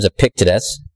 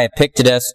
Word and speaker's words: Epictetus: Also, the first century philosopher Epictetus, again, struggling to pronounce these names, Epictetus.